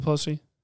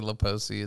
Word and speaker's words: Pelosi: Uh, did you hear about the whole uh, Nancy Pelosi? Did I say her name wrong? – Yeah, you always do. It's oh, funny. Whatever. You it's, always say Laposi.